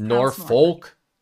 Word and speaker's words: Norfolk: It's spelled Norfolk, but it's Norfolk.